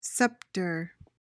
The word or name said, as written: scepter